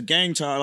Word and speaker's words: Gang Time: But it's spelled it's, it's gang time like G A N G.